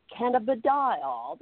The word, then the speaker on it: cannabidiol: We were sitting, I don't know, outside, and we were trying to figure out, CBD is called, we actually had to Google how you pronounce the official name of CBD. And we got this woman with a British accent somehow pronouncing it for us. And it's called cannabidiol. That's the only way we can say it now, because that's how she said it.